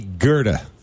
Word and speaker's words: Goethe: That would be Goethe. – Is it Goethe? – Goethe.